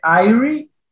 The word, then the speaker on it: Irie: Si hay un estado que llama el Rastafari que se dice IRI, Irie se escribe,